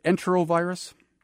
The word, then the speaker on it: enterovirus: Is it enterovirus? – That is exactly correct.